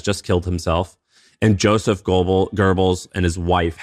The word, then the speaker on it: Goebbels: So basically, Joseph Goebbels, is it Goebbels? – Goebbels is how you pronounce it.